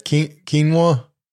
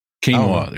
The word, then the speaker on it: quinoa: Quinoa? – Is that quinoa? – Quinoa. There you go.